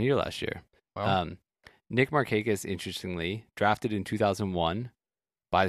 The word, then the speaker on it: Markakis: Are you sure this guy Mark Hignes is playing? – Nick Markakis? – Markakis? Oh, there he is. Nick Markakis, yes.